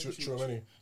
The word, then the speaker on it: Tchouaméni: Tchouaméni.